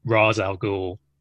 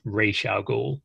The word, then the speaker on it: Ra's al Ghul: And it always annoyed me in Batman Begins that they call him Ra's al Ghul, because his name is actually pronounced Ra's al Ghul.